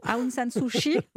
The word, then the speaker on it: Aung San Suu Kyi: D'abord, est-ce qu'on pourrait se mettre tous d'accord, si vous le voulez bien, sur la prononciation Aung San Suu Kyi, c'est ça? – Voilà.